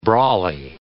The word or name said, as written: brolly